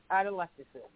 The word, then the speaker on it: atelectasis: Atelectasis? – Yes. – You know, I've always... Oh, how do you spell that? – A-T-E-L. – Oh, there it is. Atelectasis.